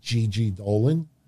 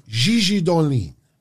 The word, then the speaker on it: Gigi Dolin: I said her name should be pronounced not Gigi Dolan. It should have been Gigi Dolin.